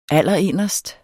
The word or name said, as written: allerinderst